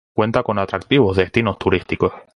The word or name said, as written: destinos